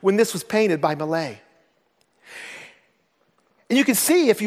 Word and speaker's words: Millet: And if you look at this, now the Cleveland pronunciation of this is Jean Francis Millet, I know, but we'll go with the French version, Millet.